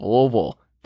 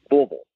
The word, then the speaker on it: Louisville: You have to say it like you have a mouthful of bubble gum, like you're a 9-year-old who just, you know, went too deep on that big league two, and you're just saying Louisville.